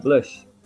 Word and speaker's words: Blutch: Se escreve, se escreve B-L-U-T-C-H, Blush. Blush é Blush.